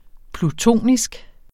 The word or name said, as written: plutonisk